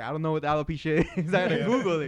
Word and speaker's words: alopecia: Yeah, I mean, not everybody knew that she had alopecia. I believe it's how you pronounce it.